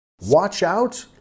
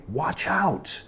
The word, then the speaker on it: watch out: Watch out, literalmente, palabra por palabra, reloj afuera, pero quiere decir cuidado, ¿ok? Watch out, cuidado. Nota la pronunciación, watch, ch, ch, watch out, watch out, watch out.